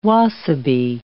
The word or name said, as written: wasabi